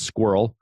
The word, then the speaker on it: SQRL: But it's called SQRL. It's like, so it's pronounced squirrel, but it's secure, quick, reliable login.